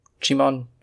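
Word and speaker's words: Djimon: Jimon? – Yeah.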